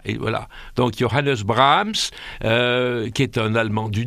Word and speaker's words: Brahms: Nous on dit Brahms, et les Allemands le prononcent Brahms, ils accentuent le A, parce que c'est une voyelle D'accord. – qui est suivie d'un H.